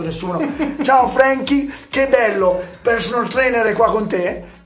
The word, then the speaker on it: PRSNL: E comunque si legge così, personal.